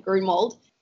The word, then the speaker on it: Grunewald: And Elsie, correct me if I've said her name wrong, but I think you pronounce her surname Grunewald, is an American distance runner specializing in the 1500 and the 5K distances.